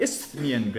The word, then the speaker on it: Isthmian: And that's not a mistranslation, mispronunciation. The Isthmian Games, okay, which were second to the Olympic Games, but still very important.